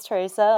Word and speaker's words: Teresa: So my name's Teresa, like very well said, Emma. Or pronounced, I should say.